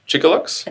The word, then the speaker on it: Chicxulub: I believe it's pronounced Chicxulub. – Chicxulub? – I think so.